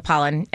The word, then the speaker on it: Pollan: Yeah, Michael Pollan. And it's interesting. I don't say his name very much. I just read it when I'm reading things. – I'm presuming I'm saying it right. – Yes.